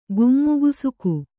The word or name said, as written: ಗುಮ್ಮುಗುಸುಕು